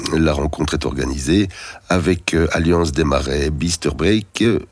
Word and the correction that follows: Bisterbrook: Non, Bisterbrook, pardon, s'écrit vraiment fort petit.